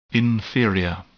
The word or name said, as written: inferior